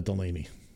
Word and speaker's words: Delany: dana delany good. Not only is she a, an actress as well, but the TV show The Animaniacs will often use her name to rhyme with the word zany or insaney.